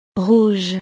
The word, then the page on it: rouge: frances